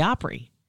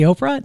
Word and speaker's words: Opry: It sounds like you're saying it wrong. – 50 years at the Opry. At the Oprah? No, at the Opry.